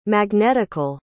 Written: magnetical

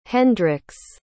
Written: Hendricks

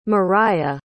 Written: moriah

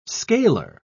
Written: scaler

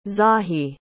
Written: zahi

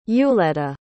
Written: euleta

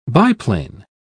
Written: biplane

